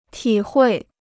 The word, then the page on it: 体会: tǐ huì